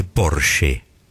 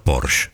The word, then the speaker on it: Porsche: La marca es alemana y se dice Porsche. no Porsche. – Ah, Porsche. – Esa E final en alemán se pronuncia.